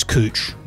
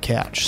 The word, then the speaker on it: couch: Because I know it's spelled C-O-W-C-H, right? – Yeah, it's a confusing one. In America, they pronounce it how it's spelt, but in Australia, it is couch, not couch.